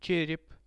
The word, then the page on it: cherep: Russian